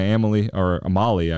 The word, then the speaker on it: Amalie: I was walking to Amalie, I believe the correct pronunciation is, but I was going to a Lightning game, walking on the sidewalk, city owned sidewalk, and there were some palm trees.